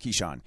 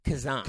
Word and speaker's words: Kizhan: It's spelled K-I-Z-H-A-N, but it's actually pronounced Keyshawn. It's Keyshawn Clark. – You think it would be Kazan. – Kazan, but it's not.